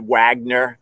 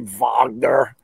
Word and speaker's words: Wagner: I was calling it Wagner because I'm from America. It's spelled Wagner, but they say Wagner.